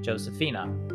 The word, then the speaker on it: Giuseppina: Her name is spelled the Italian way. It's a good thing I didn't try to look her up on LinkedIn, because it's spelled just like the Italian name Joseph, which is Giuseppe, except it's the female version, which is technically Giuseppina, G-I-U-S-E-P-P-I-N-A, but she pronounces it Josefina.